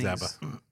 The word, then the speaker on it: ABBA: ABBA. – It's just pronounced ABBA.